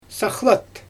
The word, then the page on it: saxlyt: Xyzyl